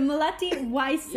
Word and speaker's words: Melati Wijsen: Did I say your name right? Did I pronounce that right? – Yeah, you said it good. It's good. Melati Wijsen. – Yeah. Wijsen. – Wijsen. Okay.